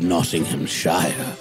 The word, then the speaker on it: Nottinghamshire: I will sing the achievements of General Ludd, now the hero of Nottinghamshire. – See, now this tells us how you pronounce it. This is the rhyme, Shire and Shire.